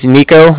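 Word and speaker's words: Niko: So my name is Nico. That's N-I-K-O.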